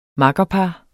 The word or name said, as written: makkerpar